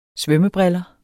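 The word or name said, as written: SVØMMEBRILLER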